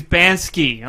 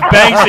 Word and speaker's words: Banksy: Yeah, he was like, it's not Banksy, it's Bansky. I'm like, no, it's Banksy, and he got really on me about it.